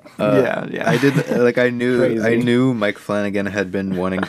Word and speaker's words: Flanagan: We watched Mike Flunagoon's Hush. – Why can't you just say his name? – It's Flanagan. – It's Mike Flanagan, yes.